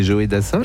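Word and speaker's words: Joe Dassin: Je ne connaissais pas cette manière de prononcer Joé Dassin. – C'est Joé Dassin.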